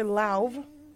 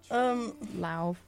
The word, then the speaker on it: Lauv: It's by the boy Lauv, which that's how you pronounce it. Strange. – Lauv. – Lauv. Like loud with a V at the end.